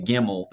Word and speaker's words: gimel: And the kid's goal was always to get the gimel, the one that kind of looks, you know, it's pronounced like a G because that you think gimel, gimme.